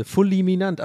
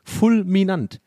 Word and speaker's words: fulminant: Also, so also für alle, die es nicht gehört haben, ich, das, ich Real Talk, ich wusste nicht, wie man Fulminant, also ich dachte wirklich, da ist noch eine Silbe, Fulminant, aber es ist ja tatsächlich Fulminant.